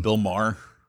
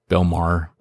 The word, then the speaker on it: Maher: Bill Maher. – Bill Maher.